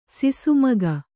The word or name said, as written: ಸಿಸುಮಗ